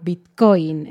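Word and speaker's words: bitcóin: Se escribe igual que en inglés, pero con una tilde o un acento en la O, Bitcoin.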